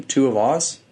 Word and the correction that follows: Tuivasa: is that it? Uh, Tuivasa. Tuivasa, yeah, it's I think Vasa.